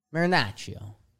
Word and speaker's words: Marinaccio: Is that how you say it? – Marinaccio. – Marinaccio. – Yeah. Your C's got a little more. Marinaccio. – Marinaccio. – Marinaccio. – Marinaccio. – I think you're there.